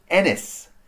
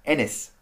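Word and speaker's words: Eness: And actually, I read that display was made by an Australian art and technology company called Ennis. E-N-E-S-S. I think that's how you pronounce it. Ennis.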